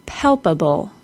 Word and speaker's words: palpable: Listen to the word again and notice where the stress is. Palpable. Repeat it after me. Palpable.